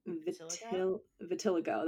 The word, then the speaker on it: vitiligo: And I'm pronouncing that wrong. Vitiligo. That's right. Vitiglio. I made it Italian. – That's okay. I called it vertiligo for a really long time. So I put an R in there.